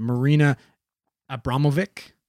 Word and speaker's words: Marina Abramović: And it's the person that like, I guess, founded is that, I'm going to butcher her name, but Marina Abramović. – That's pretty good, I think.